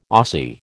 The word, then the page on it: Aussie: American English